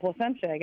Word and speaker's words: Placentia: Did I say that right? Placentia? – You did. You got that. Placentia, yep.